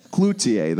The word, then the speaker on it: Cloutier: Cloutier? Yeah, Cloutier. That is the correct pronunciation.